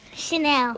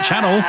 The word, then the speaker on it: Chanel: Chanel. – Oh, so you know how to pronounce it. It's not Channel.